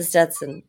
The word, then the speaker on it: Stetson: I'll get the Stenson. Is that how you pronounce it? Stetson? – The Stenson. – Stenson is a type of pencil. – Yeah, I'll get the Stetson and I will be living it up.